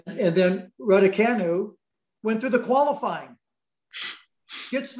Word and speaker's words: Raducanu: Raducanu. – Right, Raducanu.